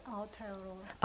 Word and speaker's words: Aotearoa: But I did recently actually travel and have a short, like 2.5 or 3 week visit to where Jill is from, to Aotearoa, is what the Maoris call New Zealand, Aotearoa. Is that how you say it? Aotearoa. Aotearoa? – Aotearoa. – Aotearoa. Okay.